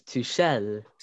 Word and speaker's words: Tuchel: Is that how we're pronouncing his name? – I think it's Tuchel.